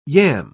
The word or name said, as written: yam